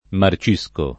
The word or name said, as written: marcire